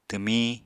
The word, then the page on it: twmiñ: Apatani